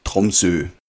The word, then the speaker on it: Tromsø: Tromsø. – Tromsø-Konvention. – Aber ich glaube, das O spricht man eher als U aus im Norwegischen. Tromsø.